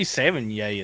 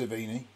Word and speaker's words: Sevigny: Chloe Sevigny is hotter than hell anyway. – I think it's pronounced Savini.